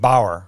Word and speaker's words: Bauer: But me personally, I'm kind of the guy who, I learned a lot from the job costing interview, and I'm not going to pronounce it's Rob Bauchner, is that it? – Bauer, Rob Bauer.